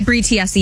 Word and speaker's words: Tiesi: How do you say, is it Tiesi? Bree Tiesi? – Yeah, Bree Tiesi, yep.